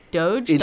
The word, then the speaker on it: Doge: I'm going to go with a hard G softy doge.